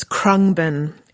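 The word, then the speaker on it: Khruangbin: I need to re-pronounce that. So it's Krungbin.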